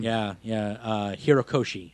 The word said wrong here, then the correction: Horikoshi: or Horo. Horikoshi.